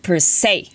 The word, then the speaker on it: per se: Now it's spelled per space say and that is P-E-R per space say is S-E そう S-E だけだと say って読まないよね普通英語だとね、 mm. でも、 yeah. SE で per se. – Yeah. – Is that the same as like I say, you say? – Pronounce, per se. Pronounce the same.